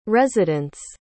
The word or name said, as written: resident's